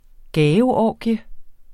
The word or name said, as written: gaveorgie